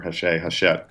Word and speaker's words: Hachette: We wound up doing a deal because the book is out on DeCapo Hachette, or Hachette, uh-huh. I believe they pronounce it.